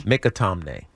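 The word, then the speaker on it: McAtamney: By the way, it is Mikatomne. – Really? – Mikatomne. – Jude Mikatomne? – Mikatomne. Mikatomne. – Oh, I said Mikatomne. – Yes, it's Mikatomne.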